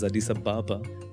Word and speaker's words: Addis Ababa: It's pronounced as Addis Ababa. But Addis Ababa.